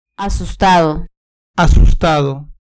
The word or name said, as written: asustado